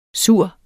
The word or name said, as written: sur